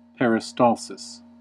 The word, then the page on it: peristalsis: American English